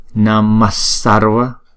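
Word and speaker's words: namaḥ sarva: nhưng mà chúng ta vẫn đọc là Namassarva.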